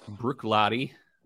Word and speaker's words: Bruichladdich: Bruichladdich, it looks like it's pronounced Bruichladdich, but it's pretty simple. It's just Bruichladdich.